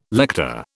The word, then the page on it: lector: British English